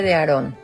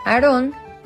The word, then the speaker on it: Aarón: aunque en español se pronuncia enfatizando la última sílaba y se acentúa en la O. Aarón es un nombre también muy común en su versión inglesa, sin acento y con la acentuación en la primera sílaba.